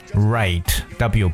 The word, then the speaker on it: write: 读成 right，w 不发音。